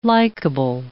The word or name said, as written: likeable